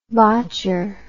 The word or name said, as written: botcher